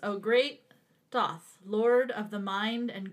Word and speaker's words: Thoth: Okay, so the first one is Thoth, I think is how you pronounce Thoth. That. It's spelled T-H-O-T-H.